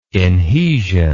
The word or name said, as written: inhesion